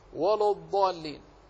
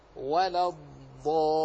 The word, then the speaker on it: الضالين: فتجد كثير من على ألسنة الناس تسمع على من ألسنة كثير من الناس ولو الضالين، وهذا غير صحيح. فالنطق الصحيح أن تنطق هكذا ولا الضالين.